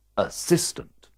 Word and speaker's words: assistant: Where does the stress fall in this word? – It goes on the I, assistant. What's the pronunciation of this word? – Which word? – This one. R-H-Y-T-H-M.